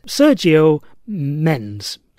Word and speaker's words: Sergio Mendes: funk and bossa nova musician Sergei Mendes. I may have pronounced that wrong. Sergio Mendes. S-E-R-G-I-O-M-E-N-D-E-S.